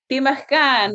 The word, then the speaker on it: Pimachkan: And he's like, Pimachkan, like pemmican, otherwise known as pemmican, more popularly known. But Pimachkan is like the the Cree pronunciation.